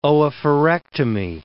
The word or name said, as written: oophorectomy